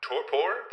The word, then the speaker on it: torpor: Torpor? That's, am I saying that right? – Yeah, you got it.